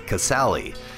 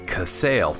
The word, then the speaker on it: Casale: Side note, I just found out that it's pronounced Casale. I was going by Casale throughout that entire episode.